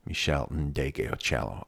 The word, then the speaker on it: Ndegeocello: See, I pronounced that okay. It's one of those names that's terrifying to look at and you think you're going to screw it up. And I said it. Michel Ndegeocello.